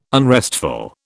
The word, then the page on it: unrestful: British English